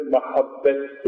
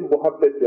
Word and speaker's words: mahabbet: Arapçada sevgi, meveddet ve mahabbet kelimesiyle ifade edilir. Biz mahabbeti muhabbet yapmışız. M harfi, dudak harfi olduğu için üstününü ötreye çevirmiş Türk telaffuzu. Aslı mahabbettir.